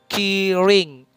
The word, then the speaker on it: Killing: Secara resmi Fantagio akhirnya mengumumkan untuk nama fandomnya Wikimiki yaitu Killing. Killing, dimana killing itu memiliki arti yang sangat dalam jadi killing itu kalau pengucapan dalam Korea kan kiring. Jadi nggak bisa L gitu kan, kiring.